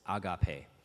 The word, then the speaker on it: agape: That's how you pronounce it, agape.